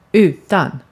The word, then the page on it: utan: /ˈʉːˌtan/